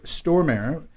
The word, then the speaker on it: Stormare: Our first guest, ladies and gentlemen, wrote, directed, and executive produced the feature film The Movie Hero, starring Jeremy Sisto and Peter Stormare.